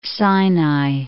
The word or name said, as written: Mount Sinai